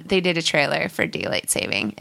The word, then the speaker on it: daylight saving: Did you know that it's actually pronounced daylight saving and not daylight savings? – Oh.